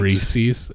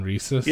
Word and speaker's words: Reese's: You could say it both ways. Like, I've heard people say can't. Reese's and Reese's.